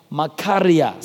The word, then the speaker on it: machaira: Now, the word for sword there is the Greek word spelled M-A-C-H-A-I-R-A, for those of you who are writing. M-A-C-H-A-I-R-A. But when you pronounce it Makaria. Somebody say Makaria. Look at your neighbor and say, neighbor, it's called Makaria.